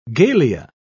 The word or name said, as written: galea